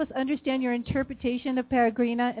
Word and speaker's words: Peregrina: Now, one of the next songs you're going to be performing for us, and I'm sorry if I slaughter the name, Peregrina. Am I saying it right? – That's pretty good. Peregrina.